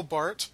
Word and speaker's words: Barth: Well, one way that we prevent the confusion is by pronouncing his name rightly. So, yes, Barth. I don't know how I can help you with Roland, except that there's no S.